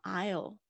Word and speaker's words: aisle: 第一个单词是 aisle，aisle，特别注意一下，不是 aisle，aisle 一个呃的声音，所以 aisle 就是走道啊、呃那我这个其实是在我们一之一的单词有讲到这个 aisle seat 啊、 aisle 啊、这个要特别注意一下，那个 s 在这边呢是没有发音的走道。